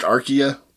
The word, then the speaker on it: Archaea: I was probably pronounced Archaea, because, I mean, the word means old, like archaeologist or archive. – Archaic.